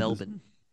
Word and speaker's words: Melbourne: This Melbourne. – I tried to drop the R there.